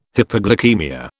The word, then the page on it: hypoglycaemia: British English